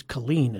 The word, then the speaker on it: Kellen: K-E-L-L-E-N. It's Colleen it's pronounced.